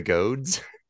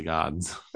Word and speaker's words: Gods: Shouldn't it be Shazam, Fury of the Goads? – I don't know. I don't think so. I think it's a short O, the way it's pronounced.